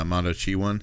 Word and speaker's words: Mondoshawan: talking about the uh, the the Mondachi one. Yeah. Okay. Yeah. So so they're the, it's spelled out like Mondoshawan, but you know they were pronouncing it in the movie as uh, Mondo one.